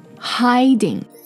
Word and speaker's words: hiding: So, okay, hiding. H-I-D-I-N-G. Heading. H-E-A-D-I-N-G. 所以它里面其实这两个单词之间发音的不同是在元音的不同上面。